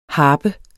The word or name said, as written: HARPERNES